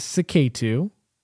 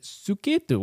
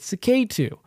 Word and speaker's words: Suketu: And a lot of people like to pronounce it Suketu, which is the proper pronunciation. But I have always grown up with Saketu. And that's the way I like to say it.